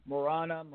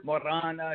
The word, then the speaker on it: Morana: Yeah, Morana. – You are Morana. If you want to roll the R, it's an Italian last name.